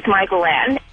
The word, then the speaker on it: Mykalanne: My name is Michael Ann, and it's spelled M-Y-K-A-L-A-N-N-E.